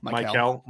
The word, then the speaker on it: Mikel: M-I-K-E-L. – It's Michael.